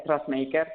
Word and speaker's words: Trustmaker: Pues mira, una empresa Trustmaker, que realmente se pronuncia Trustmaker, que es Trust, confianza, y Maker, el que hace la confianza, pues es aquella que decide crecer y desarrollarse, pero lo hace incluyendo la generación de confianza con todos sus públicos.